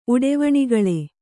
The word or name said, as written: ಉಡೆವಣಿಗಳೆ